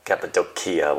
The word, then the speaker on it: Cappadocia: Yeah, I haven't been to Turkey, but I've seen many pictures of Cappadocia or Cappadocia, however you say it. – Cappadocia. – Okay, Cappadocia with a hard C.